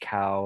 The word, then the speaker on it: Kao: My name is George Kao. Last name is spelled K-A-O. Three letters, K-A-O.